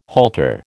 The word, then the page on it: halter: American English